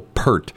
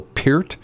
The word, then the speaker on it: Peart: Most people know him as Neil Peart, but I think the correct way to pronounce his name was Neil Peart.